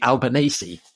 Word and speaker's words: Albanese: which is Labour under Anthony Albanese. I think I pronounced that right.